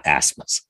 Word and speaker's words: Ausmus: Brad Ausmus, not Ausmus.